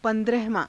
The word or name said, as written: pa^drahavaa~